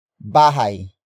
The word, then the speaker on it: bahay: Which in this case is bahay. Bahay. Bahay. Bahay is spelled as B A H A Y.